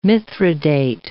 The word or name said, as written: mithridate